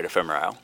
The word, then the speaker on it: Ephemerisle: When I looked at it, it took me a second, and I think I'm pronouncing it correctly, right? That's right, ephemerisle.